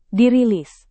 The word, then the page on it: dirilis: Indonesian